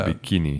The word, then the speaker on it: bikini: Wow, daarom heet de bikini nog steeds gewoon nu de bikini. – Ja. Ja. Dus, en met de klemtoon is dus op de tweede. Hè? Dus, bikini.